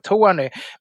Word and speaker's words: Tawny: Men det är ju alltså Tony, T-A-W-N-Y. Så man ska inte söka på T-O-N-Y på Systembolaget, utan T-A-W-N-Y. Det är väl ett efternamn, Tony, antar jag. Men det uttalas, jag vet inte om det är exakt likadant eller bara väldigt snarlikt.